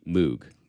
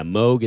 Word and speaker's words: Moog: So yeah, Moog is how I would ask people to say Moog.